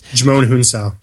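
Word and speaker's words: Djimon Hounsou: It's a strange foreign name that I can't pronounce. – Jimon Hounsou. – Ah, there you go. I completely butchered that.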